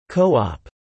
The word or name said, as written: Co-op